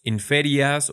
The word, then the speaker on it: inferias: En español simplemente diríamos inferias o inferias, pero en latín es inferias.